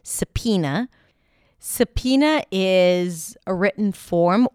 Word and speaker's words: subpoena: The next word is subpoena. Subpoena. The word is spelled with a B sound and then there is an O there somewhere.